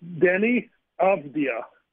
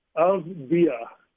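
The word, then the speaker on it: Avdija: His name is pronounced Denny Avdia. Avdia. Avdia. That is how you pronounce it.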